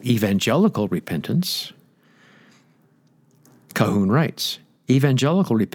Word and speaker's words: Colquhoun: John Colquhoun, pronounced K-A-H-O-O-N, but looks it's spelled C-O-L-Q-U-H-O-U-N,